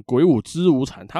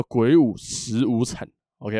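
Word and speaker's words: 鬼舞辻无惨: 这个字呢念十，叫做鬼舞十无惨。这个十指的是十字路口，只要记得这个意思啊，就等于记得念法了。这个十啊，其实就是一个中文的词，然后再加上一个错部，这个字念十。所以以后不要念成鬼舞之无惨，它不念之，它叫鬼舞十无惨，OK 吧？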